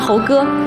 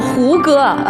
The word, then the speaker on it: 胡哥: 他知道你喜欢猴哥你喜欢猴哥，我胡哥就经常把胡哥念成猴哥他以为是西游记呢是吧那儿子呢？